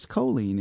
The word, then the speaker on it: Colleen: I've been saying Colleen, but it's Colleen is the correct pronunciation of that. – It is. It's like Jolene, but with a C.